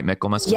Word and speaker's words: Michaelmas: Michaelmas? – Yes, I always say Michaelmas.